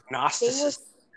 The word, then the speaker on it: Gnosticism: And if you look at the history of Gnosticism, Gnosticism. G is silent.